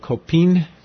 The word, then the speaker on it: COPINH: The station is being built in cooperation with an organization called COPIN, COPINH, and has a silent H in it, as almost all Honduran organizations do, because Spanish, the H is silent, but Honduras starts with an H, so you never know where to put the H.